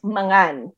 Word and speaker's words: mangan: And in Ilocano, let me know if I'm saying it right, mangan or mangan? – It's mangan.